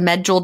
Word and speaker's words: Medjool: The medjool dates. Is that how you say it? Medjool, M-E-D-J-O-O-L. – I think so.